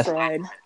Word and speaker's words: Freud: And they were also there with Sigmund Freud. – Sigmund Freud. – Is that you pronounce it right? – I believe you said it right.